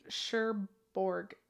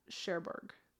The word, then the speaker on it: Cherbourg: Cherbourg. It was like, it's Cher, C-H-E-R, and then Borg, B-O-U-R-G. Cherbourg. – Yeah, Cherbourg.